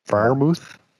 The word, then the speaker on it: Freiermuth: Freiermuth, yeah, very well.